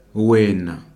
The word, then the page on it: wena: ndebele